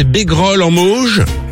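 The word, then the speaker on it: Bégrolles-en-Mauges: J'espère bien le prononcer. C'est dans le 49. Salut François. – Bonjour à la suite. – Salut François. – Salut le François. C'est bien ça, c'est Bégrolles en Mauges.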